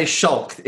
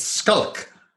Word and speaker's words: Schalk: I was like, don't say Schalk. It's not Schalk. It's Skulk.